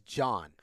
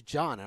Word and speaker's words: Can: And it's spelled C-A-N. – Oh, yeah. – I had no clue. There's another guy I work with who is Turkish. – Yeah. – And I showed him, like, hey, how do you say this? – Sure. – And he was like, that's John. And I was like, how is that John? – Yeah.